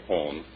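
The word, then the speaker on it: Hohn: It's H-O-H-N. Sounds like Horn. Long Island City, New York, is your good neighbor of the day. This letter is very short, but much to the point. Mrs. Horn is a nurse working 6 days a week.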